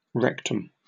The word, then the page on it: rectum: British English